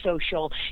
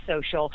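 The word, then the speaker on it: Xocial: Next, I want to talk tech-driven health as it relates to a really cool online community called Social. It's spelled with an X, X-O-C-I-A-L, but it's pronounced Social.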